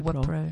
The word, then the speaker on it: Wipro: Uh, today we've got WePro, Wipro. Is it Wipro? – Wipro.